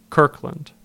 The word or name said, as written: Kirkland